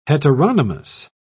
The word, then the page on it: heteronomous: British English